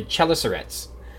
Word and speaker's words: chelicerates: The chelicerates.